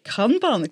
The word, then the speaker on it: Kanban: Und das eine, weiss ich nicht, ob ich es richtig ausspreche, Kanban. Ist das richtig? Kanban, korrekt.